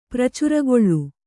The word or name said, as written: ಪ್ರಚುರಗೊಳ್ಳು